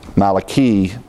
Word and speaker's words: Malachi: We pronounce the book Malachi, the I, but in Hebrew, actually, the I is pronounced as an E in Malachi. Malachi in the Hebrew pronunciation.